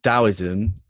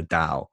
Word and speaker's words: Taoism: So Taoism, I thought it was Taoism to begin with, because it's spelled with a T, but in Chinese it's like the Tao.